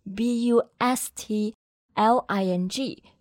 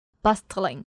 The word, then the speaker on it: bustling: b u s t l i n g 中间虽然有字母 t，但是这个 t 不发音。 – 对，所以不要读成了 bustling，而应该是 bustling，bustling。